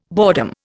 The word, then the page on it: boredom: Indian